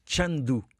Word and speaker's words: Chengdu: De, essa cidade, já agora deixa-me dizer, apesar de se escrever Chengdu, diz-se em, em, em, mandarim, diz Chandu.